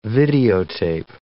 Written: videotape

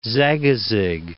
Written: Zagazig

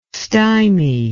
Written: stimy

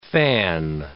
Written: fan

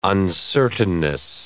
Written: uncertainness

